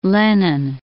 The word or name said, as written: Lennon